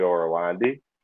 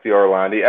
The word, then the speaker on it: Orlandi: It's called Beyond Alpha Ezio Orlandi. For Twitter, you can find me at Ezio Orlandi. Ezio is spelled E-Z-I-O, last name Orlandi, O-R-L-A-N-D-I.